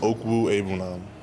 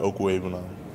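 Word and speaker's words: Okwuegbunam: Yeah, it's pronounced Okwu Ebunam. It's all together, Okwu Ebunam. – Say that again. – Okwu Ebunam.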